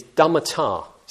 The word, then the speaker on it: dhammata: And the word naturally, or in the nature of things in that translation, is dhammata. So it's dhamma, the Pali version of dhamma, with ta, T long A at the end.